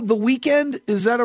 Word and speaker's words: Weeknd: It's spelled Weeknd without the E and end. – Oh, I got you. Okay. – But it's still, yeah, you still it. Pronounce it Weeknd.